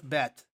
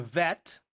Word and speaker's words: bet: If you go down to the next one, you have a bet, or sometimes pronounced with a vet, like Avenu, which would be our father, as a Av, but pronounced with a more of a V sound.